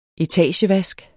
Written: etagevask

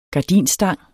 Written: gardinstang

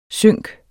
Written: synk

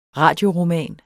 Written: radioroman

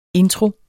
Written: intro

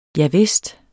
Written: javist

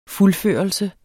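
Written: Fuldførelse

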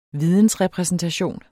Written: vidensrepræsentation

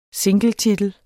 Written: singletitel

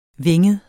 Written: vinget

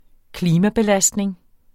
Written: klimabelastning

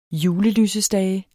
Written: julelysestage

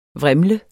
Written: VRIMLENDE

